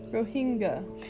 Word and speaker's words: Rohingya: So, I've heard it been pronounced Rohingya and Rohingya. Can you tell me which one it is? – Rohingya.